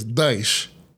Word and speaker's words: Daesh: The implication is that ISIL was somehow more Islamic or less American, even though the Arabic pronunciation is Daesh.